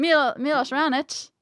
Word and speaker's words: Raonic: Renick? – Milos Raonic.